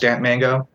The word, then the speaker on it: DAMP3DMANGO: Just type in Damped Mango, DAMP3DMANGO, Damped 3D Mango, but it's pronounced Damped Mango.